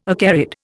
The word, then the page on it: acarid: Indian